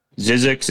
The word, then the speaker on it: Zzyzx: Zizzix is a town outside Las Vegas. It is spelled Z-Z-Y-Z-X.